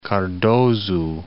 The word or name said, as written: Cardoso